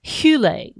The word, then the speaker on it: hyle: I ran across a reference in alchemy to the idea of hule, which is spelled H Y L E.